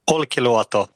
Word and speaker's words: Olkiluoto: Kan du uttala den där platsen åt mig? – Olkiluoto. – Ja, så ska det låta.